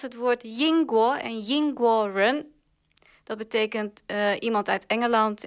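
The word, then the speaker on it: rén: Ja, dus dat gelan is Nederland, maar dan staat er nog dat woordje run. Wat we uit het Engels kennen, ja, zo spreek je het uit. Maar dat woord betekent in het Chinees iets anders.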